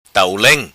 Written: Tau Leng

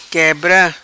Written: Quebra

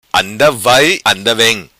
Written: anda vai anda vem

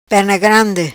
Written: Perna Grande

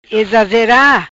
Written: Ezazera

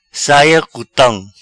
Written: Saia cutam

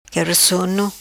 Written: Quebra Sono

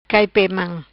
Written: Mam cai pe

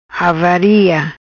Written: Avaria

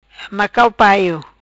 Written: Macau paio

